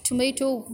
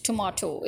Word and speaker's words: tomato: Now the the debate of tomato versus tomato is that actually tomato is the British pronunciation and tomato is the American pronunciation.